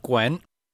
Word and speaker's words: Gwent: Some Witcher, and I fell down the rabbit hole of Gwent.